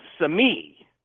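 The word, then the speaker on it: Cemí: Now, we're talking sculptures the Taíno did that are well over 1,000 years old of these little idols called Semí, and that's spelled C-E-M-I.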